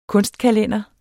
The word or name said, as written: kunstkalender